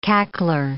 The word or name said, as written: cackler